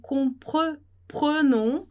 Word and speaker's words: comprenons: Então, para você prestar atenção na questão da pronúncia do E, então a gente vai falar nous comprenons, não vai ter som, esse E vai ser mudo.